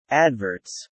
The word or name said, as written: adverts